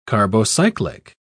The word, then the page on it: carbocyclic: British English